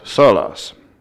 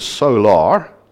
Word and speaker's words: sola: solas. And in case you heard the word solar, like the sun, what we're talking about is actually the Latin word sola, which is spelled S-O-L-A, and it just means alone.